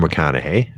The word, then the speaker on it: McConaughey: Uh, you, you don't know oh, uh, Matthew McConaughey. – Uh, hey, you oh, actually pronounced it correctly.